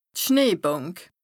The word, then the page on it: d' Schneebànk: Bas-Rhin; Strasbourg